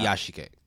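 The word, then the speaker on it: Iyashikei: Yeah, there's no NU. It's just yeah. Iyashike.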